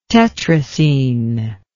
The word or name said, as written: tetracene